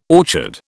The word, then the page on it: orchard: British English